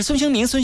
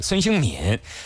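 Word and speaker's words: 孙兴慜: 孙兴敏身披的是几号球衣？ 1992年生人啊，韩国的职业足球运动员，前锋位置，现在呢效力于这个哈热刺队。孙兴敏这个敏字哈，他敏。正确读音是第三声敏，上面一个敏捷的敏，底下一个心字啊。有人说是孙兴民啊，你也可以那么叫啊。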